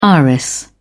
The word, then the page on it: iris: British English